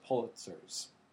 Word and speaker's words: Pulitzer: And the very first year, he gave out 4 Pulitzers. Did I get that right? – You said it. – I did. I said it correctly. – You said Pulitzer.